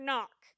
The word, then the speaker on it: knock: and K stands for knock, with a silent K.